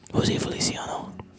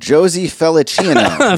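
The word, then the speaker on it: José Feliciano: We're talking about Jose Feliciano. Jose Feliciano. You could do a lot worse. – Or as Term pronounces it, Josie Felicino.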